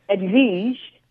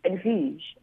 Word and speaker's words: Edvige: And my last name, B-I-N-G-U-E, is actually pronounced Bang. Very French. Edvige. See, if you now think French and roll the tongue, Edvige.